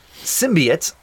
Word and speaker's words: symbiote: It is pronounced symbiote on the Spider-Man cartoons.